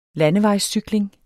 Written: landevejscykling

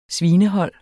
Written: svinehold

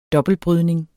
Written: dobbeltbrydning

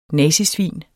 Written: nazisvin

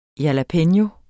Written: JALAPENO

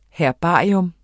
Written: herbarium

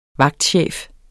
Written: vagtchef

